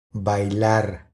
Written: Bailar

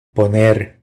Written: Poner

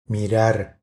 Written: Mirar

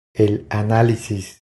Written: El analisis

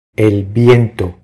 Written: El viento

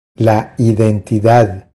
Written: La identidad

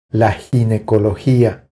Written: La ginecologia

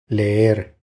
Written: Leer

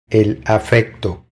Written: El afecto

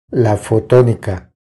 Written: La fotonica

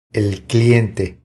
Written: El cliente

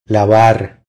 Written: Lavar

